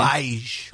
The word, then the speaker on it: Feige: Feige.